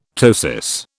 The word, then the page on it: ptosis: British English